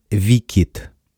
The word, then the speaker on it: WCIT: Произносится как «Викит».